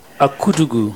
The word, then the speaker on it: Akudugu: Okay, I am Father Peter Winimi Akudugu.